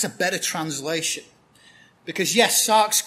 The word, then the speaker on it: sarx: It's this Greek word Paul uses, the word sarx. It's spelled S-A-R-X, sarx. Or sax, if you're from around here.